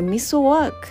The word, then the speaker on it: ミスワーク: このミスワークっていうのはね、まあなんか発音的にはミソワーク、ミソワークっていうなんかそういう感じの発音なんですけれども、まあいわゆるこれはね、なんかね、木の枝なんですよ。